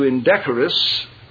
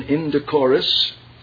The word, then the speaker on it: indecorous: And then fourthly, don't get carried away into indecorous, second pronunciation, indecorous. Either are correct, though indecorous is listed as the first.